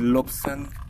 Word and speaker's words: Lobsang: Mi nombre es Lobsan Salguero.